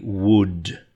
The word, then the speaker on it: would: So if I were you, were, if I were you, I would, yeah, sounds like the wood from a tree, spelled differently, W-O-U-L-D,